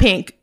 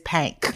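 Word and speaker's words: Pynk: Gotta say it like the Pank. – Yeah, you're right. It is not Pink. It is Pank.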